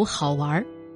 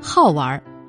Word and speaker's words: 好玩: 好玩这个读音不仅可以读好玩，说明他是个有意思的人；也可以读好玩，说明他有一颗童心，爱玩、会玩，也能玩。